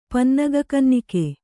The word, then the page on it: ಪನ್ನಗಕನ್ನಿಕೆ: Kannada